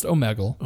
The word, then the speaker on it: Omegle: First of all, I believe it's pronounced Omegle.